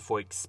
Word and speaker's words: Feucht: Sean, F-E-U-C-H-T, I think that it's pronounced Feucht, but he started to hold these outdoor worship services as a protest to the banning of religious in-person services.